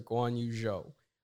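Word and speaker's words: Guanyu Zhou: Yes, we have a special guest, the first ever Chinese driver in F1, Mr. Guan Yu Zhou. We're saying that correctly, right? – Yeah, definitely. Hi, guys. It's Guan Yu Zhou here. You said it absolutely perfectly, so that's very good.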